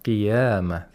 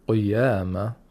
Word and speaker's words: قيامة: You pronounce the qaf with a kasra by preserving the sound of kasra. If you say قيامة, قيامة doesn't sound like a kasra. Mm-hmm. E. We need the E sound.